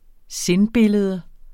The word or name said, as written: sindbillede